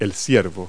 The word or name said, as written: doe